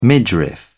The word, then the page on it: midriff: British English